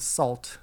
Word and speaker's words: Sault: So this song is called Fearless by the band Salt, and that's spelled S-A-U-L-T.